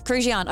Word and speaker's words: Krujian: Yes, Krujian. I've got notes. And that is spelled K-R-U-J-I-A-N.